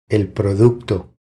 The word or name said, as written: El producto